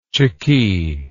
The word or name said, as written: chickee